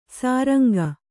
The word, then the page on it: ಸುರಂಗ: suranga